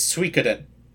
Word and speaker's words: Suikoden: The story of Suikoden is loosely based on Shui Hu Zhuan, Water Margin, a classic Chinese novel, the title of which, when pronounced in Japanese, is Suikoden.